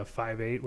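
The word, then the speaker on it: Phive Eight: Uh, they'll be on the show again soon because uh, they have they're also representing uh, Five Eight, which is spelled P H I V E eight,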